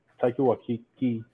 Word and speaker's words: Taika Waititi: Yo, Taika Waititi, whatever they say his name, man.